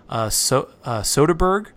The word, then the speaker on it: Soderbergh: I think it's Soderbergh.